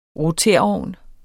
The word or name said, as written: roterovn